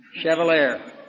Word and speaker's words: chevalier: Chevalier, which means a knight.